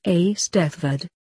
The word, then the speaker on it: Eisteddfod: Some schools hold an Eisteddfod, pronounced Eisteddfod.